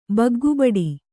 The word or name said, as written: ಬಗ್ಗುಬಡಿ